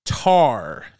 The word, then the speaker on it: Tár: which will be Tar. And yes, that's how it's pronounced, with the accent over the A.